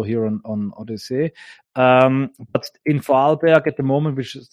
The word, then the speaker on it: Vorarlberg: I know that there are many people in Vorarlberg who completely agree with you, but are, of course, not as affected as the Viennese or the Tyroleans. – Yeah, it's by the way, it's pronounced Vorarlberg.